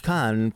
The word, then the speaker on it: Cannes: It's silent. It's a silent S. Mm-hmm. Cannes Film Festival. Oh, excuse me. The Cannes Film Festival.